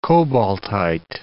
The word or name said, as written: cobaltite